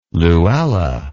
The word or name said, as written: Luella